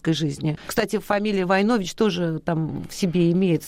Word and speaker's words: Войнович: Есть какие-то семейные предания, и есть у меня книга, между прочим, из Сербии, наш род, Войнович, правильно, ударение Войнович.